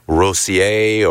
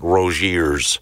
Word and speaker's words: Rossier: So now you're looking down the bench like you do, next man up, and you I've been pronouncing his name wrong. It's Rossier, or it's not Rozier's.